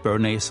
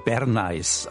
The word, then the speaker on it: Bernays: Und dort hat man den Namen nicht Bernays, sondern Bernays ausgesprochen, natürlich.